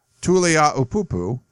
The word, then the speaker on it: Tuliaupupu: So it's Solomon, and he actually gives me the pronunciation, which is nice, Tuliaupupu, who's a four-star offensive linebacker, outside linebacker.